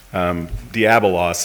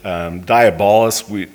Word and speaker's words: diabolos: Diabolos is the actual Greek pronunciation, but diabolos would be, maybe we would look at the word and say it in the English, but the idea is we don't want to be little devils.